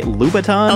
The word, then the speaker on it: Louboutin: Nicole's going to be rocking the Louboutin heels. Am I pronouncing that right? Louboutin? – Oh yeah, Louboutins.